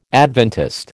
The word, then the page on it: Adventist: American English